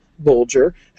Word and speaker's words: Bulger: For example, Bulger has a G as in bulge, and Matham rhymes with fathom.